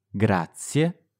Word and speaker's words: grazie: Grazie. Grazie. Grazie. – You can pronounce this sound by starting with similar sounding words in English. So you know how some people shorten the word congratulations to congrats? – Sì.